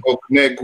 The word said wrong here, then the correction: Okongwu: Oh, Onyeka Okongwu. Yeah, Okongwu.